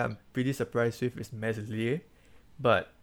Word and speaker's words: Meslier: Meslier? – Yeah, Meslier and Nick Pope are tied at 6.